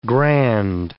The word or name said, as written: grand